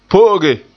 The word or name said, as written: po'oge